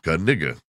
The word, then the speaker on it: Caniga: So, he says it's pronounced Caniga. You know, the K is not silent.